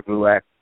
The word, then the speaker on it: Gulak: Uh, and then, you know, follow me on Twitter at Drew Gulak, D R E W G U L A K. It rhymes with attack.